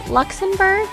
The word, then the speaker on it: Luxenburg: Her name is Brandye and is it Luxenburg? – Luxenburg. – Perfect. – That is correct. – It's phonetic, just how it's spelled, guys.